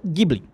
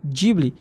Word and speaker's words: Ghibli: Mas esse nome, ele é um nome de origem italiana. Então, uhum. a hum. pronúncia seria Ghibli. Certo. Mas ele não sabia disso. Então, e ele aí? pronunciou Ghibli.